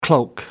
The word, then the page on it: cloak: American English